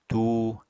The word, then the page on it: tuu: apatani